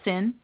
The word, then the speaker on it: CIN: The initiative, known around the agency as CIN Study, pronounced SIN, is a bit of a mystery even to insiders.